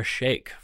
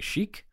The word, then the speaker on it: Sheikh: Carol, who used to live in Saudi Arabia, taught me how to pronounce that last name. I used to say Sheikh, but now I would say Sheikh.